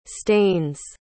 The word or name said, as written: stanes